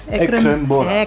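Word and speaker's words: Ekrem Bora: Ekrembora yeah. heißt du er eigentlich. Habe ich das richtig ausgesprochen? Ja, Ekrembora.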